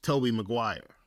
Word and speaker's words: Tobey Maguire: The best Spider-Man was Tobey Maguire.